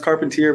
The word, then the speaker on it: Carpentier: Um, Carpentier, I, I, get that, I get that a lot. It's pronounced Carpentier, but I, I, think I got a little French in there.